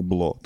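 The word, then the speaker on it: Blå: В нас був сольний концерт в клубі, який пишеться як БЛА, але читається як БЛО, тому що там А з кружочком зверху.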